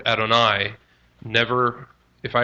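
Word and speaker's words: Adonai (אֲדֹנָי): Adonai is the alternative divine title, 449 times in the Hebrew Bible. I think your computer will show you. That's Adonai. It's spelled A-D-O-N-A-I, sometimes A-Y. Adonai, pronounced.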